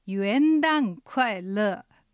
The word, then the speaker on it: 元旦快乐: で、発音はひらがなであえて書くなら元旦快乐に近いです。ちょっと難しいかな。 – ひらがなになってないかも、はいはい、で、アクセントは元旦の元が 2 声上がります。旦が下がる 4 声、快乐は快が下がる 4 声、乐も 4 声下がります。